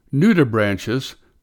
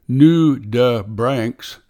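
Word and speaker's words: nudibranch: Nudibranches, pronounced various ways, including nudibranchs, are a family of sea slugs.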